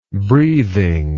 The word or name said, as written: breathingly